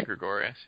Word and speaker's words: Gregorius: But when you have Didi Gregorius, is that his last name? Is that how you say it? Yeah, Gregorius, yeah.